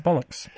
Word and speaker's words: Bolix: And one of those episodes featured a character whose name is spelt B-O-L-I-X. – Yes, Mr. Bollocks.